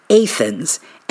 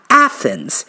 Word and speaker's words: Athens: to make sure I pronounced it Athens and not Athens, even though it is spelled exactly like the more prominent Ohio city of that name.